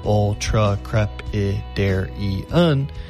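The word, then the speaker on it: ultracrepidarian: Our second word is ultra-crepidarian, pronounced ultra-crepidarian.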